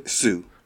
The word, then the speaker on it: Hsu: Uh, the daughter here, the daughter who's played by now how do you say this girl's name? Stephanie Hsu. Sue. Stephanie Sue.